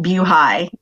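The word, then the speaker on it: BuHi: Well, first of all, I want to applaud you for pronouncing it Bew High. It is for Buford Highway.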